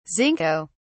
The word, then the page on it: zinco: British English